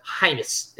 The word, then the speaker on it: Reims: Went on loan to the French league last year, scored 21 goals for I believe that's Heinz is how you pronounce that. And they're probably spelled Heims, spelled but spelled R-E-I-M-S.